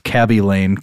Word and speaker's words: Khaby Lame: Instagrammer Cabby Lame, I think it's pronounced Cabby Lame, K-H-A-B-Y-L-A-M-E.